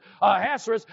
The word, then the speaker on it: Ahasuerus: She had significant influence on the decisions of King Ahasuerus, I looked it up to see how to pronounce it, Ahasuerus, and was able to save the Jews.